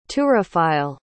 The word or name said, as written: turophile